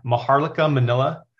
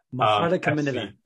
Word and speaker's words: Maharlika Manila: Remind me again of the pronunciation of the team name. – Yeah, Maharlika Manila. – Maharlika Manila, cool.